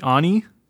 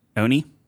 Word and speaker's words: Oni: The Oni? – Oni? – Oni? – Yeah. – O-N-I? – Mm-hmm.